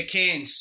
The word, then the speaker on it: Cairns: Yeah, Cairns.